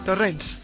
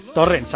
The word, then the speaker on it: Torrens: Alba Torrens, ¿cómo narices se pronuncia tu apellido? Que siempre lo decimos mal, seguro. – Torrens. – Torrens, a secas. Nada de sí. Torrens ni nada por decir, ¿no? – Torrens. – Torrens, perfecto.